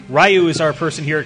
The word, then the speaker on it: Ryu: Zappy says it's pronounced Ryu. Just had to say it. – Ryu. – Ryu. Which he's right. It is. It's Ryu. I mean, when you look at like the Japanese pronunciation, how they pronounce their vowels, it's Ryu. He's absolutely correct.